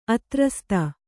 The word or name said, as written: ಆಧಾರಸ್ಥ